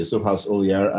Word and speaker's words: Suhas Uliyar: You pronounced my name perfectly, Suhas Uliyar.